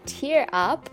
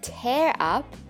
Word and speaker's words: tear: Be careful there with the pronunciation difference between tear up, que significa lagrimear, and tear up, que significa destruir o romper algo en mil pedazos. Both these verbs are pronounced in different ways, but are spelt in the exact same way. So, T-E-A-R, tear up.